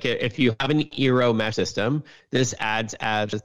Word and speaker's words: Eero: the Eero, and that's spelled E-E-R-O, it's fantastic.